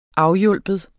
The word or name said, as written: AFHJALP